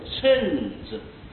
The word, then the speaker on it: 称: 这个字啊，本来是称字，称赞的称字，但是这里要念作秤字，秤啊，就是这个我们称东西的秤呐、啊，一斤两斤啊，几斤啊，现在用的公斤啊，这是秤子，现在的磅也是一样的啊。